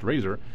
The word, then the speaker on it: RAZR: I wish I could play tunes on my RAZR, pronounced Razor,